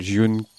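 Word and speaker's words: Jyunken: C'est de la dub de Malaisie qui s'appelle Jiunken. Okay. Je pense que c'est son vrai nom. Ça s'écrit J-Y-U-N, plus loin, Ken, K-E-N. – Comme Ken le survivant.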